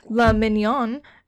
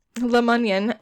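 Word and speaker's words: LaMunyon: So after this, in 1984, the chief Le Mignon I'm sorry, I shouldn't say it like that. – Le Mignon. – Lemunyan established a task force devoted to BTK's crimes, and they nicknamed them Ghostbusters.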